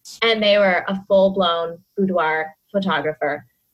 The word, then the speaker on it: boudoir: It sounds just like every facet that you work with kind of does that, especially boudoir. And I say that word weird. Am I saying it right? – Boudoir.